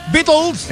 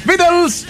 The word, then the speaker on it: The Beatles: ¿Cómo se dice? – Beatles. – Eso. Beatles.